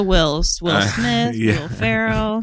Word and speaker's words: Ferrell: Wait, how do you say it? – Farrell? With an L at the end instead of Farrell? – It's Will Ferrell. – Ferrell.